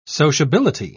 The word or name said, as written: sociability